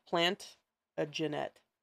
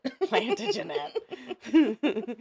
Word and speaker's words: Plantagenet: It looks like plant a Jeanette. That's what it looks like. – Plantagenet. – Plantagenet. – Say it right.